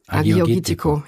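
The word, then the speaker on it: Agiorgitiko: Das ist so witzig, ne? Bei den griechischen Wörtern, dann spricht man das zuerst so richtig langsam wie so ein Vorschüler aus und dann hast du es aber auf einmal so, ne? So Agiorgitico. – Aber die heißt Nemea wird geschrieben N-E-M-E-A, ne? – Nemea, und das ist so witzig.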